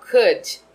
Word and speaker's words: could: Soletrando o modal could, temos C-O-U-L-D, C-O-U-L-D, mas com a pronúncia de could.